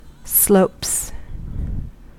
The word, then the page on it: slopes: American English